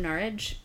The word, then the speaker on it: Norwich: Are all from Norwich, which is how you say it. Norwich or Norwich. In the accent.